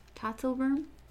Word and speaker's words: Tatzelwurm: We have the Tatzelwurm. I learned it earlier because Chad told me how to pronounce it. Tatzelwurm? Tatzelwurm? But it is. Yes, Tatzelwurm.